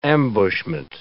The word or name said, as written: ambushment